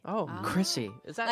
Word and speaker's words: Crissy: Did I pronounce it with an H or without an H? – Well, you pronounce it with an H, but it has no H. – There's no H in it. – What do you mean he pronounced it with an H? – Silent C. – He pronounced it C-H-R-I-S-S-Y. – I pronounced it Chrissy. – It's Chrissy. – And it's pronounced C-R-I-S-S-Y. – Right. Say both. – So instead of Chrissy, you say Chrissy.